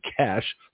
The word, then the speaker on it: cache: a cache, that's C-A-C-H-E, a cache, sometimes pronounced a cache, but it's a cache of weapons known to be stored at Concord.